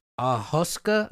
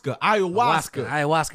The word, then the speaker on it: ayahuasca: Ahuska. – Ayahuasca.